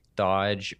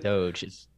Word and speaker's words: Doge: I listened to the BBC for most of my news and they were talking about how Doge was one of the most mispronounced words of 2021. Because they thought it was like Dodge or Doggy. It's like, no, when, it's Doge.